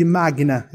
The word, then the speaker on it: magna: Magna Mater, magna de magna, é porque se pronuncia magna, né? Em latim.